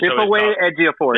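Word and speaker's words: Chiwetel Ejiofor: I just Chip away, Ejiofor.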